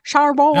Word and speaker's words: Chambord: Chambord.